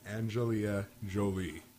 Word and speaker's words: Angelina Jolie: Jennifer Aniston and Angelina Jolie.